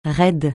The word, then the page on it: raide: francais